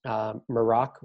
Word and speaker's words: Maroc: Domain advertisements in So I saw not really anything too crazy interesting, but the Morocco country code is .MA because Morocco, how they say it over there is Maroc, which is MAROC.